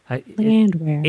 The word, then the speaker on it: Landwehr: Land where? Like, where is the land? – Oh, see, now I'll remember it. – Land where? – Anna Twinney.